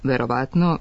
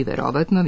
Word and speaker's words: verovatno: Stoga, umesto verovatno više spratnica, očigledno, prevashodno, radijator, svojevrstan, svakodnevno, treba reći verovatno više spratnica, očigledno, prevashodno, radijator, svojevrstan, svakodnevno. Akcenat će se na isti način prenositi i u stranim rečima koje se češće javljaju sa silaznim akcentom unutar reči.